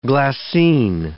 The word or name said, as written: glassine